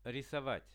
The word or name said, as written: risovat